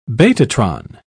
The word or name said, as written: betatron